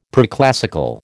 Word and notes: preclassical: American English

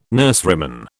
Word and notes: nurseryman: British English

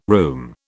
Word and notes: room: British English

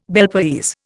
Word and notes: Bel Paese: Indian

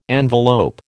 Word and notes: envelope: American English

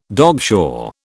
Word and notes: dogshore: British English

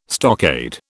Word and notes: stockade: British English

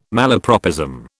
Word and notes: malapropism: British English

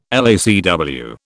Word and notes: LACW: British English

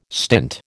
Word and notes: stint: American English

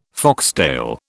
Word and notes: foxtail: British English

